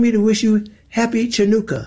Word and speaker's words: Hanukkah: Sorry, isn't it spelled with an H? – It's spelled C-H. There's a couple different ways to spell it. But, Chanukah. – But I mean, if you've been around 80 years, you should know that that's Hanukkah, or look it up instead mm-hmm.